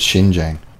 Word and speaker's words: Xinjiang: Tibetan, I forget how to pronounce the X. I think it's Xinjiang.